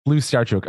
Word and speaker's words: Slyusarchuk: Slyusarchuk.